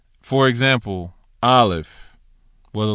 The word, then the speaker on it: Aleph: That's why it's Aleph with the F sound at the end.